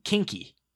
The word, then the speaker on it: quinqui: It's three films, um, and they're it, it's pronounced um, kinky.